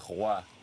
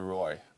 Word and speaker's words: Roy: In French it's Руа. – Понимаю, да. – But so many people cannot pronounce it the right way that since I started playing professional in the US, I switched to Roy because it's easier.